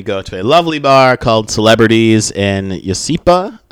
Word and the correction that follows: Yucaipa: Did I say that right? – Yucaipa, I think it is.